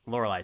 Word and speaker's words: Lorelei: Lorelei? – Lorelei Single. Thank you.